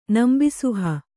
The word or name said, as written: ನಂಬಿಸುಹ